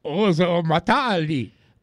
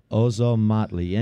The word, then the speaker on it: Ozomatli: I want to hear you pronounce the name of this first band. – Ozomatali. – Ozomatali.